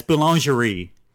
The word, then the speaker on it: Boulangerie: Yeah, it's Boulangerie.